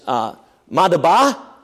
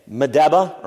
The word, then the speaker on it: Madaba: Yeah, I don't have my laser pointer here this morning, but I want you to notice right here is Madaba, is how they pronounce it today. Madaba or Madaba is how they pronounce it in Arabic.